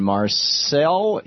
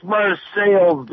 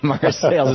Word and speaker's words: Marseilles: Marseille. Is it Marseille or Marseilles, Illinois? How do you pronounce it? – It's Marseilles. There's nobody in town that can speak French. – Okay. Marseilles has been anglicized.